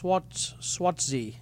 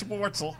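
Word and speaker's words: Schwartzel: Charles Swartzy. Is that how you pronounce his name? – No. Nah, Charles Swartzel. Swartzel.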